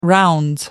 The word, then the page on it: rounds: British English